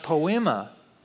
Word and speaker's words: poema: The word workmanship, you are God's workmanship, that's actually a Greek word that is pronounced poema.